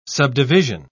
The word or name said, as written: subdivision